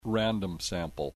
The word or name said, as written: random sample